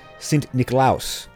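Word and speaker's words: Sint Nicolaas: Now, in Dutch, St. Nicholas is pronounced Sint Nicolaus, which evolved into a nickname, Sinterklaas.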